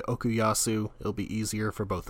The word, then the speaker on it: Okuyasu: Okuyasu. – Okuyasu, okay. – Or Okuyasu, they seem to kind of go back and forth on it.